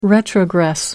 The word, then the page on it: retrogress: American English